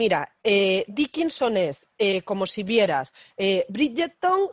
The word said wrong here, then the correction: Bridgerton: Como pues si Tom brillara, Bridgerton. Ah, Bridgerton, ya está.